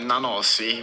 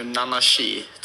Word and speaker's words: Nanasi: men hur uttalar du namnet? – Ja, vi säger med Nanasi. Men jag tror på ungerska säger man nu Nanashi, tror jag. Men Nanasi är det annars.